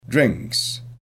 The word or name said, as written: drinks